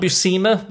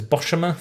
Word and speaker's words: Buscema: John Buscema, but I used to pronounce it as Boshima for some reason.